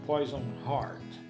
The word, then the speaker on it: Poison Heart: Poison Heart.